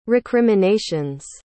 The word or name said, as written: recriminations